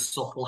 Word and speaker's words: subtle: Did you know that the word subtle has a silent B in it?